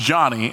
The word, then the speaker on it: Dsane: My last name, which is spelled D-S-A-N-E, is pronounced Johnny, and it's from Ghana, West Africa.